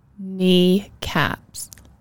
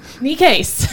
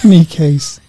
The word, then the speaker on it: Necaise: Kneecaps. – No. – Necaise. – Necaise. That's how you remember how to say it.